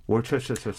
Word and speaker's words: Worcestershire: Worcestershire sauce. – It is not pronounced the way it's spelled.